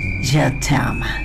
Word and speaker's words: Je t'aime: Y se pronuncia así, Je t'aime.